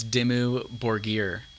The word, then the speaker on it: Dimmu Borgir: I'm probably not pronouncing this right, because no one ever knows how to pronounce it. It's Dimmu Borgir. – I've never even heard of that. – D-I-M-M-U. New word, B-O-R-G-I-R.